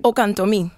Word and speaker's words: Okantomi: is it Okantomi? Am I saying that right? – Okantomi. – Okantomi. – There you go. – All right. Let me introduce you to the, that was Elizabeth Rodriguez, you just heard, correcting my pronunciation.